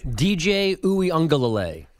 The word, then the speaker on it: DJ Uiagalelei: DJ Uiagalelei.